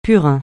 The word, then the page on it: purin: francais